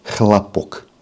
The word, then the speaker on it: хлопок: По-русски это хлопок. Ну а если переставить ударение, будет хлопок.